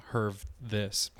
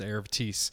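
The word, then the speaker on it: Hervé This: His last name is spelled This. It's not Herve This. It's Erv Thies.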